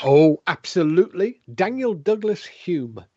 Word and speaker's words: Home: It's not home. It's Hume, by the way. – What do you mean it's not home? – The name is spelt H-O-M-E, pronounced Hume. – By who? – It's a Scottish name.